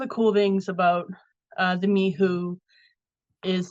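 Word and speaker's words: Mhuwe: So I want to talk about, I'm going to spell it first. It's M-H-U-W-E. And I believe the correct pronunciation is Mihu.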